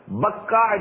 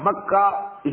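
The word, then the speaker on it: Bakkah: Bakka and Makka is the same word.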